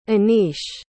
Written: Anish

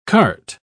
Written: carte

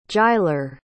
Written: jyler

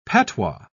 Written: patois

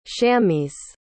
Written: shammis

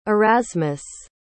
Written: erasmus

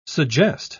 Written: suggest

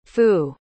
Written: fou

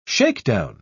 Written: shakedown

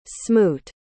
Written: smoot

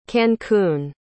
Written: cancún

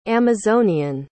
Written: amazonian

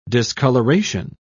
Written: discoloration

